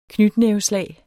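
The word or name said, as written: knytnæveslag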